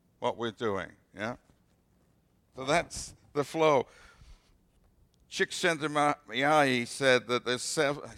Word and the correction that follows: Csikszentmihalyi: I've been saying it wrong all this time. – I love his book, too. – Okay, you love his book, too. And you can actually pronounce his name. Okay. Okay. Say it again. Mihai Chick sent me high. Cheek sent me high. The cheek sent me high. I got it now.